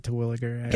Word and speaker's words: Terwilliger: I've always pronounced it Terwilliger. – Good job. – That is correct.